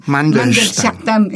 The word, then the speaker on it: Mandelstam: Mandelstam. – Mandelstam.